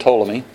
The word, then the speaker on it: Ptolemy: But it came to be known as the Ptolemaic system because it was codified by a man named Ptolemy. And if you can spell that, you get extra points. It's like trying to spell Chapatulus. It starts with a T. Ptolemy starts with a P, believe it or not.